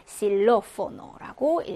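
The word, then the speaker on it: xilófono: 이거는 이제 단어의 첫 글자로 쓰일 때 S 발음 난다라고 했습니다. 그래서 실로포노라고 읽어주시면 되죠.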